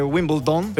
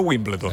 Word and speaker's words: Wimbledon: scusate, tu sai che tutti lo dicono nel modo sbagliato? Tu come lo dici? – Si dice Wimbledon. – Esatto. Invece tutti dicono Wimbledon.